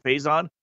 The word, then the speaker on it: Phaizon: So with that, 2 days ago, yesterday, another big-time recruit, a wide receiver, Faison Wilson, I believe his first name is pronounced, P-H-A-I-Z-O-N, Faison, tweeted that he has some thinking to do, and he's not necessarily now living up to his commitment to go to Texas.